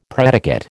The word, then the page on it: predicate: American English